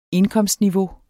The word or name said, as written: indkomstniveau